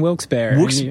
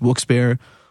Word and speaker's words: Wilkes-Barre: But there's I think three possible pronunciations. There's Wilkes-Barre, Wilkes-Barre. – Yeah. – Which is, according to the information that I received, that's the proper pronunciation. It's two French guys' last names.